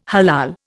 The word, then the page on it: halal: Indian